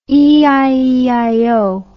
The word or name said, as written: eieio